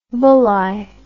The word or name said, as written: Bulli